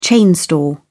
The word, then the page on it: chain store: British English